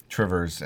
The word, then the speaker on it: Two Rivers: Trivers, as we pronounce it in Wisconsin.